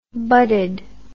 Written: budded

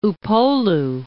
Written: Upolu

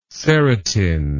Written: serotine

